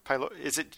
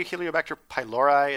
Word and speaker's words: Helicobacter pylori: But one of you guys want to maybe tell the history of Helicobacter pylori? Do you guys say Helicobacter pylori? Is that the commonly accepted pronunciation? – That sounds right.